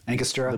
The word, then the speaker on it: Angostura: Angostura.